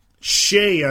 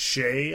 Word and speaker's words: Skjei: Shea on defense, and I pronounce it, and you might not recognize it. The spelling is Skjei. – Mm-hmm. – It's pronounced Shea, I've been told.